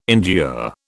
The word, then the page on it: endure: British English